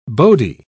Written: bodhi

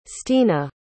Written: stena

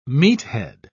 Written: meathead

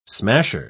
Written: smasher

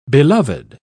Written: beloved

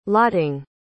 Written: lotting

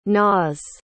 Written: naas

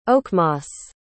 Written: oakmoss

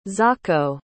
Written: ntsako

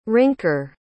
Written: rinker

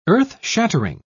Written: earth-shattering

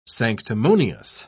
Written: sanctimonious

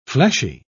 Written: fleshy

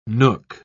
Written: nook

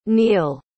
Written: neil

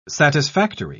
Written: satisfactory

